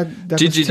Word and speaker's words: D'Agostino: Gigi da, D'Agostino.